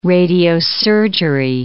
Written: radiosurgery

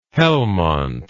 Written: helmont

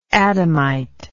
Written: Adamite

